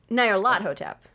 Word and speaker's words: Nyarlathotep: A lot of people say Nyarlathotep, but it's Egyptian. – That's the right way to say it. It's the right way to say it. – Nyarlathotep.